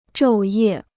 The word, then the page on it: 昼夜: zhòu yè